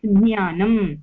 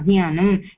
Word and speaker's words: ज्ञानं: ज्ञ ज्ञ दिस् ज इस् आल्मोस्ट् नाट् हर्ड् ज्ञ ज्ञानं वदतु ज्ञानं सो इट् यु आर् एक्चुलि जिप् युर् टङ्ग् स्टार्ट्स् वित् ज उच्चारण बट् इट् गोस् टुवर्ड्स् ज्ञ खलु